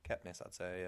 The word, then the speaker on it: Kepnes: I don't know how to pronounce the surname, Caroline Kepnes? – Kepnes, I'd say, yeah.